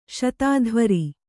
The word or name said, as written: ಶತಾಧ್ವರಿ